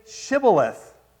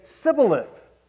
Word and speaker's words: shibboleth: You see, it seems that the Ephraimites could not pronounce S-H. They could not pronounce S-H. And so they would say to them, all right, say Shibboleth. But they couldn't say Shibboleth. Ephraimites instead would say Sibboleth. Difference between an S-H and an S.